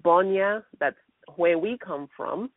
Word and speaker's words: Bonya: I'm actually writing another book, and it's called Bonya, which is spelled B-O-N-Y-A. It's Bonya, a father's covenant, because that's where my father came from.